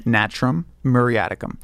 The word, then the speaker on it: Natrum Muriaticum: It's actually the medicine that I gave in that circumstance was Natrum Muriaticum, which is sea salt. – Really? – Yeah. – How do you say it again? – Natrum Muriaticum.